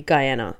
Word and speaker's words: Guyana: I think we're going to decide that our pronunciation for this will be Guyana. – Guyana. – And we acknowledge that there are other ways to pronounce it.